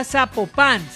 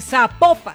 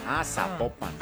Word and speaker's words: Zapopan: Oiga, nos escriben, nos escriben los oyentes y que no se, no se pronuncia Zapopan, sino que se pronuncia Zapopan. – Zapopan.